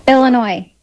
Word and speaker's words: Illinois: Illinois.